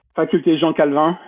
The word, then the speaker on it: Faculté Jean Calvin: Faculté Jean Calvin.